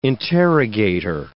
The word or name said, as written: interrogator